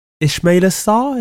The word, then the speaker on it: Ismaila Sarr: Sarr, is it Ismaila Sarr? Is that how you say his name? – Yeah.